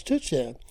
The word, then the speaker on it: tourtière: Tourtière.